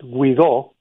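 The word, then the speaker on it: Guaidó: He sent a message to the Venezuelan people, estamos con ustedes, we're with you, announcing the new president before the new president, so-called Guaido with an accent on the O, had even declared himself the, quote, interim acting president.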